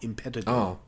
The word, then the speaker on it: Impetigore: He was the director of Impetigore, which I found out it's actually pronounced Impetigore, not Impetigore. – Oh, Impetigore. Yeah. Okay, cool. Good to know.